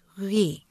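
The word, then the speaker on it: Reuilly: It's R-E-U-I-L-L-Y. So it looks like Ruy, but it's really Ruy.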